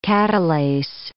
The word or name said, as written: catalase